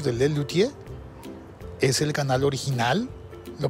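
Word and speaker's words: Les Luthiers: Lelutier. Se escribe Les Lutiers con TH.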